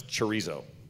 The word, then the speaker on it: chorizo: How do you pronounce chorizo? – You just did. – Crap. – That's. I mean. – Dang it! I did do it right, didn't I? – Yeah, you did. All right. Just pronounce chorizo.